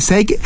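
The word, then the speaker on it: Zweig: Es de un autor eh, muy interesante, Stefan Seig. Oh. E se escribe Z-W-E-I-G y se escribe Seig.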